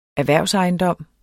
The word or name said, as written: erhvervsejendom